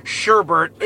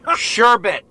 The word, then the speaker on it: sherbet: when there actually is no such word as sherbert in the English language, Greg. It's sherbet. Sherbet. Not sherbert. There is no such thing as sherbert.